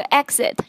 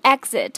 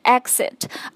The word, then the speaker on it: exit: 注意啊，这个退出或者是表示出口的这个 exit 这个单词，它的发音是 exit 或者是 exit，而不是 exit。